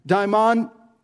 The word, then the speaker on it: daimon: Daimon, daimon, D-A-I-M-O-N.